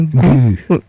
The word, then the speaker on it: Guu: Alors, vous l'écrivez G-U-U, mais ça se prononce bien Gou.